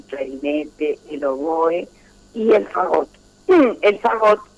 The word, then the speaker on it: fagot: Bueno, el fagot, muy bien pronunciado, es un instrumento de viento y de la familia, en todas las orquestas hay fagot.